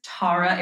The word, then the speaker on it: Tara: My name is Tara. It's like Tara, but it's more pretentious.